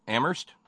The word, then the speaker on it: Amherst: Thank you very kindly to Terry in Amherst, where only the H is silent.